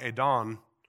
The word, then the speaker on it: Adon: When you look at the word, there's one word that is pronounced something like Adon, and it is Lord, but notice it is a lowercase L.